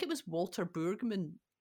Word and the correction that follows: Brueggemann: Uh, eh, that is how you say his name, Neil. – Brueggemann. You talk about him a lot. – I read one, yeah, Brueggemann.